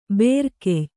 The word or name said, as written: ಭರಿಕೆಯ್